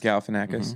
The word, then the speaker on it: Galifianakis: Zach Galifianakis. – Galifianakis.